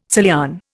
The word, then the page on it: Salian: Indian